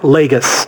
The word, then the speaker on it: Lagos: No, it's Lagos if it's in Portugal. The one that's in Nigeria that she damaged is pronounced Lagos. – That's like, Lagos. – Like Gus? Gus. – Like Nagus. Lagos. Okay. Like Nagus from Star Trek, but with an L.